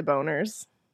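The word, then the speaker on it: Bonerz: But okay, guys, it's B-O-N-E-R-Z. – It's totally Boners. – All right. So yeah. – Boners. – That is so unfortunate. – Yeah. It's probably it Boners. – I don't know. – But no one's probably ever pronounced it that way. – I mean, bone is B-O-N-E. Just saying. – Yeah. It's true. – And the R and the Z.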